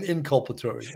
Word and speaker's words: Inculpatory: So tonight you learned cop, exculpatory, and inculpatory. All right. I had heard exculpatory many times before. The inculpatory, is that how you pronounce it? That's how you say it? – Inculpatory. – Inculpatory. Yeah.